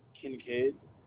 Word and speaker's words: Kincaid: Kincaid. – Okay. Spell that for me, Kincaid. – K-I-N-C-A-I-D.